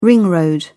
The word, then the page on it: ring road: British English